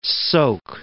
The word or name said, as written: soak